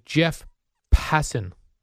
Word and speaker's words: Passan: A great article by Jeff Passan. Did I say that correctly? – Yes, you did.